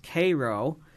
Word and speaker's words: Cairo: Downstate, it's pronounced Cairo. There's a community less than 2 hours east of Macomb called San Joe's, but it looks like San Jose. So if you're not from this region, you don't know it. You don't know that pronunciation.